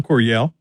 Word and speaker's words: Coryell: Help me pronounce the last name. – Don Coryell.